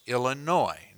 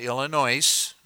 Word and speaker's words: Illinois: Folks, it's pronounced Illinois, not Illinois.